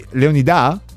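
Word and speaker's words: Leonidà: Mia figlia invece, scrive qualcuno, l'ho chiamata Leonida con accento sulla A. Quindi Leonida? – Leonida.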